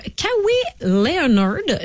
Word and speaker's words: Kawhi Leonard: Dans l'actualité aujourd'hui, il y a Kawhi Leonard.